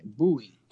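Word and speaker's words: buoy: No, it's B U O Y, Bowie.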